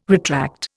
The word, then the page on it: retract: Indian